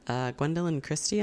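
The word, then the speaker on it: Gwendoline Christie: It's got Gwendolyn Christie on the cover.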